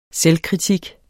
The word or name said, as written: selvkritik